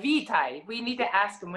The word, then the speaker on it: vitae: Vitae. Vitae. You want to hear it again? Listen. – Vitae. Vitae. – That's how you Vitae. Say it right there.